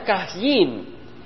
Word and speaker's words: Caín: Ahora, Caín en el hebreo se pronuncia Cajín, y juega con la palabra Cana.